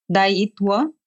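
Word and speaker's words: Daayitwa: It's D-A-A-Y-I-T-W-A. How do you pronounce it? – Da-it, like tea. Daitwa.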